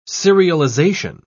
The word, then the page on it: serialisation: British English